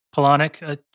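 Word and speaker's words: Palahniuk: Is that how you pronounce Poloniak? – Polonic, I think. – Polonic.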